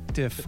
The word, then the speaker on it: DIFF: D-I-F-F. – And there's a lot of voicemails being left at 612-888-DIP. – It's not DIP. DIFF. As in different styles.